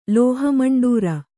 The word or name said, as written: ಲೋಹಮಂಡೂರ